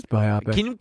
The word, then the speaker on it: biopic: Can we Google how to pronounce biopic? Because I think it might be a GIF-JIF situation where even if it's pronounced the way that it is, the way that it should be, due to the popular... – Biopic. No, it's biopic.